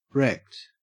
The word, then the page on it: wrecked: Australian English